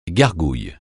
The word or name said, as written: gargouille